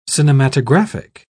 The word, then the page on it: cinematographic: British English